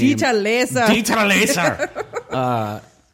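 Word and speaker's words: Dieter Laser: Dieter Lasser.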